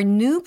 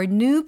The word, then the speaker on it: new: 근데 여기에서 new라는 것을 new라고 할 수도 있어요. 미국식 영어냐 영국식 영어냐의 차이인데요. 미국식 영어는 new 라고 발음하는 경향이 더 있습니다. 영국식이 new 라고 발음하는 경향이 있고요.